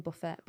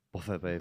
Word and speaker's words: Buffett: Is that how you say his name? Buffett. Buffet. – Yeah. Buffett, baby. – Buffett. – Yeah. Not Buffet.